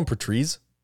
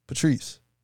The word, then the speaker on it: Patrice: You call him Patrice? – Patrice. – Patrice? – Patrice. That's how you say his name.